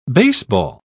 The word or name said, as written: baseball